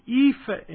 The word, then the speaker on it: EAFE: And MSCI has another index called the EAFE Index. And that's how it's pronounced.